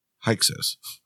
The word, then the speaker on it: Hyksos: Pronounced Hyksos.